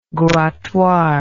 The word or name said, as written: grattoir